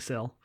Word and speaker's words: Cyl: Yes, I, um, I was saying Sil.